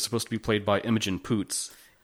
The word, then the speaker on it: Imogen Poots: Imogen Poots over and over and over again.